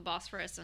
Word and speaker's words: Bosphorus: In the plane, we got to fly over like, you know, the European side over the Bosphorus onto, am I saying that right? – Yes.